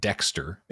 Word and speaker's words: DXTR: I'm going to say it's pronounced Dexter. It's all caps DXTR.